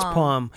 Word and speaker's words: palm: How do you say palm? – Palm. – Palm. – It's palm. Don't. – Palm? – Don't. – Do I just pronounce the L's in everything? – You do. – Palm? – Brendan does the same thing.